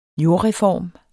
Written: jordreform

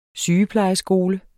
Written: sygeplejeskole

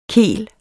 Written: kel

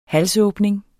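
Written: halsåbning

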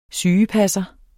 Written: sygepasser

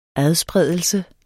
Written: adspredelse

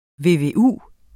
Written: VVU

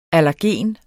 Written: allergen

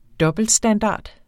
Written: dobbeltstandard

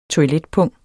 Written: toiletpung

